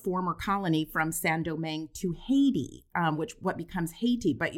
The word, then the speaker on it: Haiti: And they call the place Haiti. Am I pronouncing that correct? – That's right.